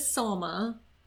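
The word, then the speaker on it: Salma Hayek: Selma Hayek. – Okay, her name is Salma.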